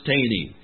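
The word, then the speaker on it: Thoene: Now, if you have no idea how to spell Taney, don't expect it to be T-A-N-N-Y. It's T-H-O-E-N-E, but it's pronounced Taney.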